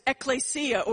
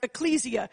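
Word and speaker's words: ekklesia (ἐκκλησία): The Greek word is ekklesia, or you may have heard it pronounced ecclesia.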